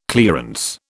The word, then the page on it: clearance: British English